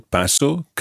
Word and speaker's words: paso: Yeah, just always remember that the stress is on the first syllable in parts 1, 2, 3 and 6. Paso, pasas, pasa and pasan at the end.